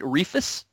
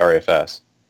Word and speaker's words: ReFS: And people, do you guys not pronounce it REFUS? No, I've never heard anybody pronounce it REFUS. Uh, we, I usually hear people pronounce it REFS.